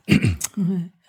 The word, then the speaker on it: foudre: Foudre.